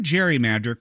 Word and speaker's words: gerrymander: But when the term gerrymander caught on, it wasn't with the hard G sound of Gary's surname.